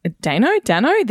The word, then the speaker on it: Dano: So, I think Paul Dano's Dano? This is a new thing. I've been saying his name wrong forever, apparently.